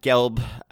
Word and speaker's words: Gelb: As Matt Gelb, I do believe it is Gelb, oddly enough, the creator of GIFs, has weighed in to say that it is, in fact, Matt Gelb. That's how you say it. Hard G.